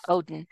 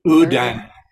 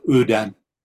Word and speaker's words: Odin: The Norwegians regarded Balder, the beautiful, as the solar deity, and Odin, I said that wrong, say it right. – Odin. I only learned that in our section on Odin, but yeah, Odin. – Okay. – I think we had a Norwegian that actually gave us the right pronunciation.